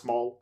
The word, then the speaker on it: moll: Fathery, do you know the term like from like the 1930s of Mull, like Moll? I think it's still pronounced Maul.